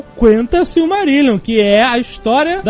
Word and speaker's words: Quenta: É Quenta ou Quenta? – Quenta. Se pronuncia todas as letras.